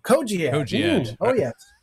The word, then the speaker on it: Cogiad: Cogiad is spelled C O G I A D if I had animation, I would put it on the screen here.